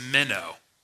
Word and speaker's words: meno: It's meno. Okay, M-E-N-O. It's only two syllables.